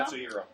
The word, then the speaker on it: Katsuhiro: Well, Akira originally started as a manga in the early '80s by uh, how do you pronounce it? Kashiro Tomo? Katsuhiro. Katsuhiro.